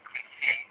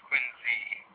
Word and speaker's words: Quincy: otherwise pronounced in other areas of the world where there's same spelling, but they pronounce it Quincy, like Quincy, Illinois. Up here it's Quincy, the Z, even though it's spelled the same.